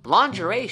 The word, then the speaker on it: lingerie: Physical should be F-I-S-I-C-A-L, but that's fiscal. What the heck? How about lingerie? Lingerie is spelled L-I-N-G-E-R-I-E. It looks like it should be read lingerie. Lingerie should be spelled L-A-W-N-J-E-R-Y. Lingerie.